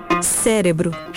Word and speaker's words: cérebro: A forma correta é cérebro, com R. Cérebro. E não cérebro. Por que as pessoas confundem? Porque tanto esse re quanto esse le você realiza na mesma região, com um pouquinho só de diferença.